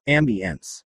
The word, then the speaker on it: Ambience: We thought Ambience a lovely name for a ship, said Wilson, pronouncing it in the French style, Ambience.